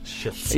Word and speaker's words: schists: Schists.